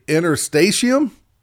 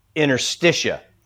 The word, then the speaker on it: interstitium: You said the interstitium. Interstitium? – Well, so I actually pronounced it as I had heard it, which was interstitia.